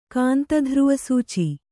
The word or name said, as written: ಕಾಂತಧ್ರುವಸೂಚಿ